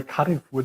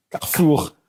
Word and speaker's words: Carrefour: Spricht man die? Ich habe immer Carrefour dazu gesagt. Okay, mein Französisch. Also Carrefour.